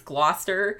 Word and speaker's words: Gloucester: If I'm not actually right about that, then I'm going to sound like a fucking idiot, but I'm pretty sure it's Gloucester. – Sure. All right. – Like Gloucester.